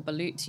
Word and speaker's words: balut: But like, I saw, is it the balut? Is that how you pronounce it? Or balut? You know, the... – Oh, yes. Bird, yes. Embryo. Balut, yeah.